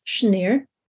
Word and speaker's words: Senir: But we know that Mount Hermon and Senir, and sometimes it's pronounced Shenir, may be the same mountain.